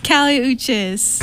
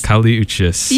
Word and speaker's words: Kali Uchis: It's Kali Uchis. – Kali Uchis, yes.